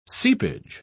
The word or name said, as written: seepage